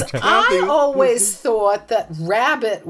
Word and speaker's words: rarebit: It's a toasted cheese dish, and it's called Welsh rarebit. – Oh, sure. Uh-huh. – Or is it Welsh rabbit? – Yeah, rabbit. Like, I always understood it was supposed to be rabbit, but it's spelled rarebit.